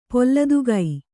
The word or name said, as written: ಪೊಲ್ಲದುಗೈ